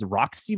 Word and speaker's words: Rocsi: I think her name is Roxy, but it's spelled R-O-C-S-I, which is ridiculous.